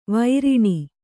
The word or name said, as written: ವಾರಣಿ